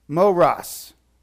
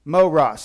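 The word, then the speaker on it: Moros (μωρός): Moros. Moros. Sounds like the word moron. Moros.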